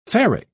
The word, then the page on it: ferric: British English